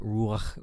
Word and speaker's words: ruach: Yeah, so we looked at the word, the Hebrew word of ruach and yes, you do have to get your throat into it a little bit to pronounce it properly, but ruach was the word that we had.